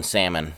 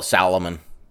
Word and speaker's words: salmon: That they should just go ahead and start pronouncing the L in salmon. Just call it Salomon. It goes along with every other weird crap that we got going on nowadays.